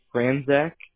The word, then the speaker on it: Franczak: So the first name is going to be G-E-O-R-G-E. – Okay. – And then the last name is going to be Franzak, F-R-A-N-C-Z-A-K.